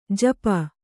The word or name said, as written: ಝಾಪು